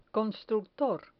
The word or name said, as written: constructor